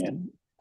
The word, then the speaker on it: Jungian: So you're a Zen Buddhist teacher and a Jungian. Am I pronouncing that right? Psychotherapist. Jungian. Okay. Mm-hmm.